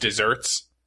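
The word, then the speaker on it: desert: There's a word called, that is in fact pronounced dessert, but it's only spelled with one S, and it's a word all on its own.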